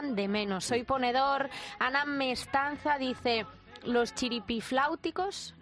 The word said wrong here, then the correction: Chiripitifláuticos: ¿lo he dicho bien? – Eso es difícil de decir. – ¿Chiripitifláuticos? – Sí, algo por ahí, sí.